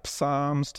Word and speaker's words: Psalms: If you are not familiar with the Psalms, it can seem like a funny word, can't it? Psalms, to say it, but it's the P is silent.